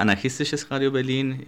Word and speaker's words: Anarchistisches Radio Berlin: Tú pronunciarás mejor el nombre completo. – Bueno, en alemán se llama Anarchistisches Radio Berlin y que no significa otra cosa que Radio Anarquista de Berlín.